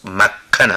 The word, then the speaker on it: مکھن: مکھن متوجه شوید، تلفظ مکھن است.